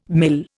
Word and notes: mil: Indian

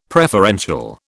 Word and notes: preferential: British English

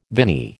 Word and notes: viny: American English

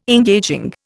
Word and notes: engaging: Indian

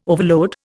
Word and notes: overload: Indian

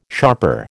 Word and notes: sharper: American English

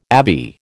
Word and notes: abbey: American English

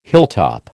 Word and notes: hilltop: American English

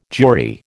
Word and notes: Jewry: American English

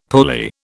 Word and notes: pulley: British English

